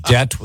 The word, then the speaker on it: debt: Debt with a silent B.